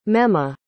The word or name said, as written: mema